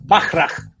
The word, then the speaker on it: Bachrach: Es, es, alemán, así que se pronuncia Bachrach. Bachrach.